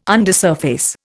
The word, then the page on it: undersurface: Indian